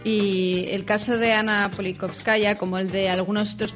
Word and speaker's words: Politkovskaya: Politkovskaya. No sé si lo he dicho bien. – Exacto, Ana Politkovskaya.